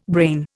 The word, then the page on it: brain: Indian